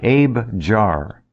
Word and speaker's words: Abejar: Spell it. A-B-A-H-A-R? Abahar? – A-B-E-J-A-R. – Ah. Like Abe Jar. Okay. – Right. Abe Jar.